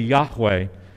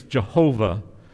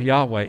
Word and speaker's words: Yahweh: You know, in Hebrew, that name, according to scholars, is pronounced probably Yahweh. It was inappropriately translated earlier on in earlier translations, Jehovah. But it is really Yahweh.